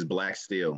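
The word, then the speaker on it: Blaque Steele: So my moniker or pen name or pseudonym, however you want to label it, is Black Steel. So Black, I spell it different. It's B-L-A-Q-U-E, and then Steel is S-T-E-E-L-E.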